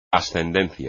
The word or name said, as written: ascendencia